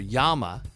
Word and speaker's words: llama: When a llama or yama, depending on how you want to pronounce it, spits, they're actually spitting out vomit.